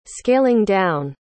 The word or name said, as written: scaling-down